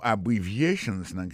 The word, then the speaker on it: abbreviations: En parlant de accent, accent tonique qui vient pour nous prononcer mot yo, n'a pas un mot abbreviations, qui signifie abbréviation. Abbreviations, accent tonique là, tombé sous troisième syllabe là.